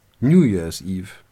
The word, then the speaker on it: New Year's Eve: Bei New Year ist die Betonung auf dem zweiten Wort und es verschiebt sich, wenn man das dritte anfügt, aufs erste Wort.